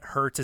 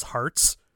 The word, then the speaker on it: Herts: And he's like, in England, Hurts is actually pronounced Hearts.